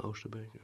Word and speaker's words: Oosterbeek: Oosterbeek. – Mm, yeah, Oosterbeek.